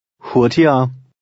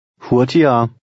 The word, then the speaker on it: hurtiger: Remember the sound of the G in the middle of a word. – Hurtiger. Hurtiger.